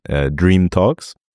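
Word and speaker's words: Dreem Talks: Also Name kann ich sagen und das wird heißen Dream Talks.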